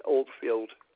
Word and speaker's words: Oldfield: Johnny Oldfield. – Spell that last name for me, Johnny. – O-L-D-F-I-E-L-D.